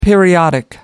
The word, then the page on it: periodic: American English